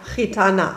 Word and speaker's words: Gitana: Rumba Gitarra. – Naja, Gitana, aber ist nicht so schlimm.